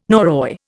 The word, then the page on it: Norroy: Indian